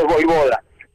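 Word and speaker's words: Vojvoda: Y además confirmando que su apellido es Voivoda. – Claro. Se pronuncia de esta manera.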